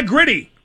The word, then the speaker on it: Gritty: Yeah, Gritty. Rhymes with pretty.